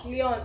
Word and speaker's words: Lyon: Is it Lyon? – Lyon.